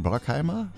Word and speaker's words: Bruckheimer: Bruckheimer? Ich glaube, das wird jetzt amerikanisch. Da wird das U wie A ausgesprochen. Ne? Jerry Bruckheimer, würde ich mal so sagen.